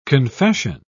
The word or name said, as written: confession